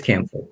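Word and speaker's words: Camphire: and it was A Cluster of Campfire, or maybe I'm mispronouncing Camphor. – That. – I think it's Camphor.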